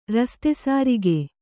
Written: ರಸ್ತೆಸಾರಿಗೆ

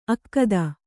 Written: ಅಕ್ಕದ

